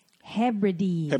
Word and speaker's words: Hebrides: Come to find out this gentleman out of nowhere, Manchester, England, listened to our podcast and said the actual pronunciation is Hebrides.